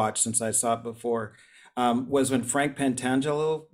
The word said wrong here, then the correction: Pentangeli: Pentangeli, remember.